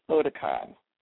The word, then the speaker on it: Otakon: I'm going to say it right, Okatan 13? Or is it Oktan? – It's Otakon.